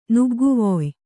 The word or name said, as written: ನುಗ್ಗುವೊಯ್